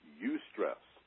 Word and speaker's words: eustress: There is something called eustress. This is an important concept, more than a word. Eustress is spelled E-U and the word stress. E-U-S-T-R-E-S-S. Eustress.